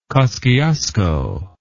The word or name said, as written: kosciusko